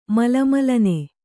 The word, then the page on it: ಮಲಮಲನೆ: mala malane